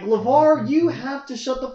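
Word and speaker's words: LaVar: LeVar, yeah.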